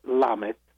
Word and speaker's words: Lamet: And Eric's name is spelled with a C, E-R-I-C. And yep. – Lamet is spelled L-A-M-E-T.